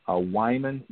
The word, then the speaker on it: Wyman: So Wyman Winbush, W-Y-M-A-N, last name is W-I-N, B as in Baker, U-S-H.